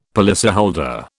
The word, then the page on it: policyholder: British English